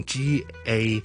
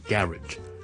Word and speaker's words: garage: 有听众问萧叔叔，车房我哋英文点样讲？Garage，系咪有几个发音咧？咁事实上的确系有几个发音嘅。先讲英式英文先。英式英文有两个发音，一个是一个比较英文化的发音，就叫做 garage，前面是 ga 大口，跟住咧后面咧系 h，好似一个 j 咁嘅音嘅，就咁 garage。